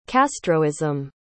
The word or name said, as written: castroism